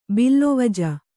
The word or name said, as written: ಬಲ್ವಜ